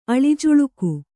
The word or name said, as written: ಅಳಿಜುಳುಕು